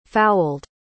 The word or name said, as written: fouled